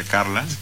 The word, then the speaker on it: Carles: Y también porque, bueno, porque saben, una vez la anécdota con Carlas, que se escribe Carles y se pronuncia Carlas, uh-huh, es que Fabián le regaló un compilado de discos de Atahualpa Yupanqui y Carla dice, no, acá faltan tres discos.